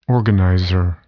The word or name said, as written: organizer